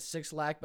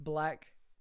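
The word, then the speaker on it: 6LACK: Probably know him as Six Lack, but it's pronounced Black.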